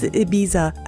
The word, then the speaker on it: Ibiza: It's Ibiza. Actually, I read a whole article about the proper pronunciation of this. Island, Jesus Christ, in Spain. And there are arguments about how you pronounce it, but Ibiza is what I've come to.